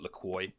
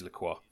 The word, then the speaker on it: Lacroix: And it is Lacroix to our American listeners. It is not Lacroix, or however you horribly mispronounce that word. It is Lacroix.